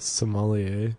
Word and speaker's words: sommelier: Sommelier.